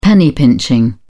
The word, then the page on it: penny pinching: British English